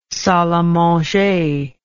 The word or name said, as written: salle a manger